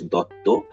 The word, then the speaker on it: Dutta: So as you have said, my name is Ashis Dutta. In Bengali, it's pronounced Ashis Dutto, but okay. Dutta is absolutely fine.